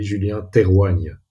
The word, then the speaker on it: Julien Terwanga: It's a bit with the Flemish accent, so the proper pronunciation would be Julien Terwanga.